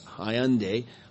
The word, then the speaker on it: Hollande: I mean, even now, Hollande, I don't know if I'm pronouncing exactly right. It looks like Holland, but it's Hollande.